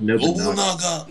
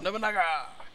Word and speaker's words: Nobunaga: Takaru uses Musashi and Mikoto uses, it's pronounced Nobunaga. – Nobunaga. – Nobunaga.